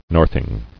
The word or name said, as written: Northing